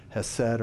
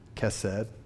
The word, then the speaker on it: hesed: We've talked about the word in the Old Testament for loving kindness, which is hesed or kesed, however you want to pronounce it.